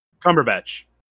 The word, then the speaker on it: Cumberbatch: And it's got, it still has the originals, Benedict Cucumberbatch, whatever you pronounce that, Cucumberbatch? – Cumberbatch. Yeah.